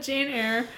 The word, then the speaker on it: Eyre: I'm listening to the most recent episode with Jane Eyre, I don't know Eyre. How you pronounce it. – Jane Eyre.